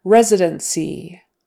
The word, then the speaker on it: residency: That final E and NC, it's said more like a, a short I, like silver. Residency. Um, you probably wouldn't say residency. It's just one of those quick throwaway vowels, residency, because that syllable is not stressed. Re is stressed, residency, and so you want to get that vowel correct. Residency. And of course, the C is like an S, residency.